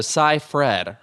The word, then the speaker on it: Seyfried: And there are some shots of Amanda Seyfried. Her name rhymes with rye bread.